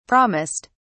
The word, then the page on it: promised: British English